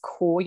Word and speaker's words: Caur: I do have online yoga and meditation as well, which is the yoga brand that I work under is Core Yoga. So that's spelled C-A-U-R. And basically that's those letters stand for come as you are,